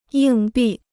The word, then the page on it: 硬币: yìng bì